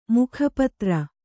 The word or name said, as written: ಮುಖಪತ್ರ